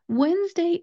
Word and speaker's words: Wednesday: Wednesday is not spelled how it is pronounced. If you look at how Wednesday is spelled, it should be pronounced Wednesday. Wednesday. Oh, that sounds weird, doesn't it? That is not how we pronounce that word. We have actually changed the pronunciation of this word over time because if you look at the spelling, there is a D there. That D is not pronounced. So when you are trying to say this word, do not pronounce that D.